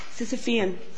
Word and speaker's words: Sisyphean: I was just trying to pronounce Sisyphean. – The Sisyphean error, Sisyphean. How it was doing. – Sisyphean. Good. – Sisyphean.